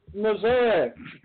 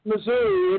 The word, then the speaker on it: Missouri: Missouri. Not Missouri, it's Missouri. Sometimes yes. – Pronounced misery. It can be pronounced misery sometimes.